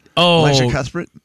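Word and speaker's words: Elisha Cuthbert: Is that how you say it? Elijah Cuthbert? – I think that's right, actually.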